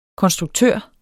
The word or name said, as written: konstruktør